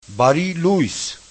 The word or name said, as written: bariluys